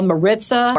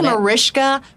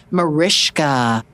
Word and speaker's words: Mariska: I get called Maritza, Marcika, Mariska, Markiska, M-A-R-I-S-H-H-H-H-H-H-H-H-K-A. That's how I put it on the call sheet. But it's Mariska, Mariska.